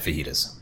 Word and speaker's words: fajitas: Yeah, the one exception being Chili's had fajitas. – Mm. – Fajitas. – Mm. – Why? – That's how it's pronounced.